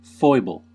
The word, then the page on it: foible: American English